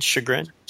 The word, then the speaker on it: chagrin: How do you say that word? Chagrin? Chagrin? – Chagrin. Yeah, you got it.